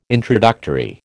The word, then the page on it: introductory: American English